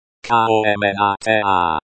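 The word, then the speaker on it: komnata (комната): Komnata.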